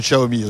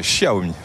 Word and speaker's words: Xiaomi: Avec la prononciation de Xiaomi aussi. – Tout à fait, Xiaomi.